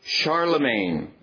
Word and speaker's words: Charlemagne: This M-A-G-N-E in his name, the G is silent, Charlemagne, that M-A-G-N-E is derived from the Latin magnus, meaning great.